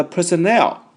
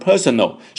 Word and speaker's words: personnel: 好，那么来看看这里这个家伙他亲自说服了谁啊，说服了 the personnel, 注意 personnel。那么这个单词的话呢，它的发音就要注意了，不是念成 personal, 是 personnel。 Personnel, 重音在 N-E-L 这里 Personnel, OK 啊 Personnel, 那么它表示什么意思呢？